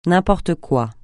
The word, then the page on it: n'importe quoi: francais